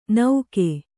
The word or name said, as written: ನೌಕೆ